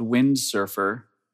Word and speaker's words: Wyndsrfr: My wife and I perform as a group called Windsurfer. But it's spelled strangely. It's WYNDSRFR.